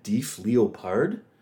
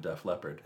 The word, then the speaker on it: Def Leppard: And when I read the article, I was like, they were going to call the band Def Leopard. – Wow. – Because in my head, And of course, that would have been better. – But it was, that was just me mispronouncing.